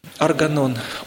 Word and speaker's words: Органон: «Органон». «Органон». Правильно, с ударением на первый слог.